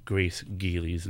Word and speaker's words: Grace Gealey: I feel very, very smart and fancy right now because I have not been mangling Grace Gealey's name all day today. I've been saying it right.